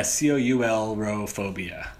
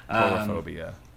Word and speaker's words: coulrophobia: But no, she's got coulrophobia. Is that how you say it? The fear of clowns? – Yeah, coulrophobia. – Coulrophobia.